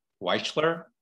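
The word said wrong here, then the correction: Wechsler: Do I have that right? Am I pronouncing that right, Matthew? – Herbert Wexler. – Yes. Wexler.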